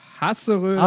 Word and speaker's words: Hasseröder: Hasseröder.